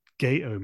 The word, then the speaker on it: Gatoh: I pronounce it Gato Move, and that's how I've heard like Aki say it and things like that. But my editor at Inside the Ropes magazine is from up north, and he pronounces it Gato Move, as in alligator, Gato Move.